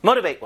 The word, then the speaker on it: motivate: 요 T 발음이 완전히 리을처럼 북미권 발음에서 바뀝니다. 영국 발음에서는 안 그래요. 근데 미국 발음 기준으로 하면 이 T가 리을로 바뀌면서 motivate 이런 느낌이 납니다.